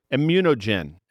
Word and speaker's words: ImmunoGen: Did I say that correctly? – Immunogen.